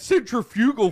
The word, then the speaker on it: centrifugal: Yeah, it's centrifugal force.